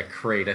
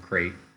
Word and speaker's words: Krayt: Yeah, crate. I think it's in Star Wars, I think it's spelled Krayt. It's pronounced the same way as the movie or the planet from episode 8, crate.